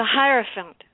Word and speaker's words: Hierophant: The Hippophantus? – I can't say it either. It's the one that starts with an H. – The Hierophant. The Hierophant.